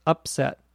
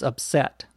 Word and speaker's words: upset: To upset, and notice the accent on the verb is on the second syllable. So when it's a noun, it's upset. And when it's a verb, it's upset.